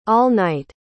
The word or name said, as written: all-night